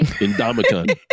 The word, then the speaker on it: Ndamukong: Ndamukong.